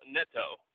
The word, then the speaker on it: Neto: And then Raul Neto, who is going to kind of be the – Is it Neto? I already messed up the pronunciation, didn't I? – I love it. It's my favorite thing about you. So it is technically Raul Neto. – Raul Neto. – Raul Neto, yeah.